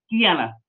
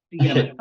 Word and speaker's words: Deanna: Deanna. You pronounced it around correctly in the beginning. Yes. Deanna. Hello, everyone. My name is Deanna Ries, and I had reached out to Jake actually quite some time ago now.